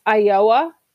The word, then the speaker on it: Ayoa: It's Ayoa. It's spelled AYOA.